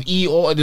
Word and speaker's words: Eeyore: And you have Eeyore, like the donkey, and I've seen.